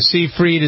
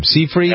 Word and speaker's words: Seyfried: Seyfried? – I think that's right, yeah. – Seyfried? – You got it. You were right the first time.